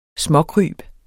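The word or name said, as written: SMÅKRYB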